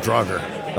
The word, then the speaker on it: Draugr: I pronounce it Draugr. – Okay. So you lose 2-1. That's how I pronounced it. – Draugr. Okay. – So there you go.